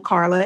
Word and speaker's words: Karla: Karla is spelled with a K.